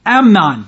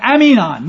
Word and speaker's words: Amnon: Not Amnon, but Aminon, which is a totally different name. Reverend Long says this, Absalom's pronunciation of Amnon is unique to the Old Testament and may represent a disparaging phrase.